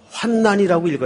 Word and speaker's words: 환난: 제가 지금 발음을 사실은 잘못했습니다. 성경에는 환난이라고 읽어야 맞습니다. 환란과 환난이 틀리더라고요. 뒤에 란자가 붙으면 앞에 환란이라고 읽는 게 맞아요. 그런데 뒤에 난자가 붙으면 국어학적인 발음상 환난이라고 읽는 게 맞습니다.